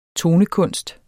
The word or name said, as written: tonekunst